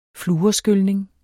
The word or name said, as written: fluorskylning